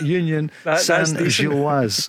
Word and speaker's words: Union Saint-Gilloise: As Grado tells me, it's Union Saint-Gilloise.